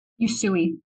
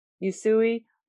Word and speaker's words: Usui: As a UC Reiki master, did I say that right? Usui. Usui Reiki master, Melissa guides her clients through deep mind, body, spirit, skin transformations.